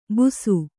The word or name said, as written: ಬುಸು